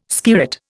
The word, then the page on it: skirret: Indian